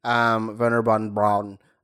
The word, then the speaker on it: Wernher von Braun: Like you meet Wernher von Braun. That's how you pronounce it.